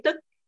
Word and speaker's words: tích: Tích chứ không phải là tức. Tích. – Ça s'écrit comment? – T-I-C-H. – T-I-C-H.